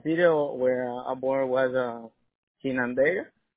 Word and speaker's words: Chinandega: It's C-H-I-N-A N-D-E-G-A. Like a China, like China Giga.